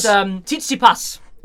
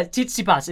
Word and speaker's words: Tsitsipas: It was Tsitsipas. It's actually how you pronounce it, yes. – That makes no sense. – Yeah, Tsitsipas is how you pronounce it.